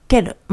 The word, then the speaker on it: quelle: えー、続いて、けるですけれども、このケるは、やはり前回第 25 回にで扱った、えー、ケル、まあ発音同じなんですが、えー、女性系単数です、えー、今回の方が後ろに LU が足されてついてるんですね。